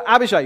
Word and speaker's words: Abishai: Abishai? Is that the right pronunciation? Anybody in the room? Abishai? You can nod, and then I'll be fine. Yes, there we are. Thank you, Carwin. Yes. So Abishai.